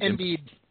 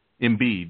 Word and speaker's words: Embiid: And Embiid. – Embiid, yeah.